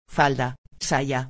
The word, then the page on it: falda, saya: espanhol